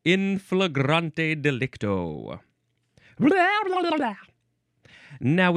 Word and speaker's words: flagrante delicto: This, I think, is pronounced flagrante delicto. But maybe you can say flagrante delicto.